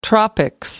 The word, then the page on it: tropics: British English